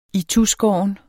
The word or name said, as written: ituskåren